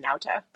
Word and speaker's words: Nauta: I believe the first name is Joaquin. I'm not sure, but the last name is pronounced Nauta.